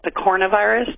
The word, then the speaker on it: picornavirus: You call them pico, the family is it's called picornavirus. That's how you pronounce it. But if you look at the spelling, it's picorna virus.